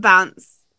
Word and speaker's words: bants: What I'm trying to say, you call it bantas, but is that we got good bants? We got good bants. That's how you say it in the UK. We got good bants. – Good what bants? – We'll see you tomorrow. Bands. – It's like short for banter.